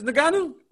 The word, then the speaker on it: Ngannou: Francis Ngannou.